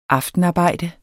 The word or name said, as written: aftenarbejde